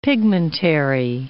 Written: pigmentary

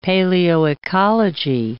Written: paleoecology

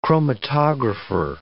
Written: chromatographer

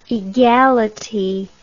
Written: egality

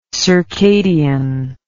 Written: circadianly

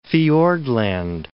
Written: Fiordland

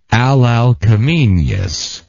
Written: Alalcomeneus

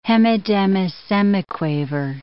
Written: hemidemisemiquaver

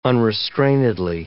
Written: unrestrainedly

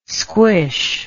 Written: squish

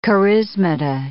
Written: charismata

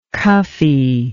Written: cuffee

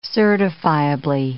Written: certifiably

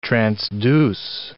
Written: transduce

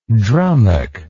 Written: drummock

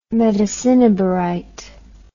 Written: metacinnabarite

